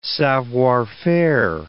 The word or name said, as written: savoir faire